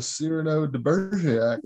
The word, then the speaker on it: Cyrano de Bergerac: with Rom playing almost like a Cyrano de Bergerac. – Yeah, it kind of was. – Mm-hmm. Yeah. And I probably butchered the hell to say that. – No, you didn't. You got that right.